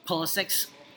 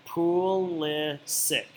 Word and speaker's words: Pulisic: Biggest thing coming out of that match and last week's podcast is that we don't know how to pronounce Christian Pulisic's name.